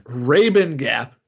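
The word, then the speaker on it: Rabun Gap: I want to know, where is Rubin Gap, Georgia? Because that sounds like a porn star's name. – Rabin Gap is how it's pronounced.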